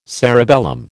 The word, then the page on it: cerebellum: American English